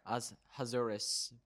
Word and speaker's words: Ahasuerus: Ahasuerus, both near and far, to have them observe the 14th and 15th days of the month of Adar each year.